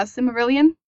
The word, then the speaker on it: Silmarillion: The Silmarillion, I think that's how you pronounce it. And Silmarillion? – My other, yeah, Silmarillion.